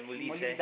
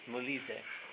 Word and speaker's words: Molise: Molese, si dice Molise. – Dal Molise. – Molise.